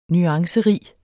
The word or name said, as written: nuancerig